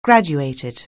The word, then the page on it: graduated: anglais